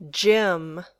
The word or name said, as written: gym